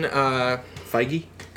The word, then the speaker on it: Feige: Pronouncing it like it rhymed with beige. – Kevin, that's what I originally thought. Feige. But in all the interviews, they call him Feige.